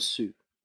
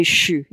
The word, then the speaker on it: suis: So suis becomes sous. – Right, or it's actually shu, yeah, is more like how it's pronounced.